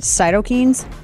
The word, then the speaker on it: cytokines: Cytokines? Right? C-Y-T-O-K-I-N-E-S? – Yeah.